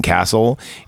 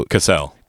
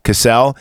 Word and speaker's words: Cassel: Vincent Castle. You, you pronounce it differently though. Cassel. Cassel. – Yeah. I always thought it was Castle. That's because you're not French.